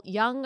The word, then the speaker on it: Jung: But when you read about the history of psychology and really self-introspective psychology, of Carl Jung, or is that how you pronounce his last name? – Yeah.